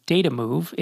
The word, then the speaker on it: Detamoov: which is Datamove? It's spelled D-E-T-A-M-O-O-V.